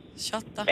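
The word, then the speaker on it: Shorta: Den danske film Shorter. – Ja, er det den der udtalt? Shorter? – Shorter.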